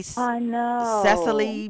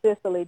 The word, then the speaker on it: Cicely: I think she pronounced it Cicely, didn't she? – I think she pronounced it Cicely, yeah. – Right. – Some people say Cecily, though, too. But, yeah, she pronounced it Cicely.